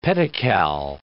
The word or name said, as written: pedocal